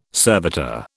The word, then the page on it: servitor: British English